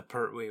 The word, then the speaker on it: Pertwee: Yeah, I did like the Pertwee one. That one was great. – He actually said his name correctly.